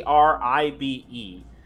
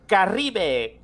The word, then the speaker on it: Caribe: Caribe. That's exactly how it sounds.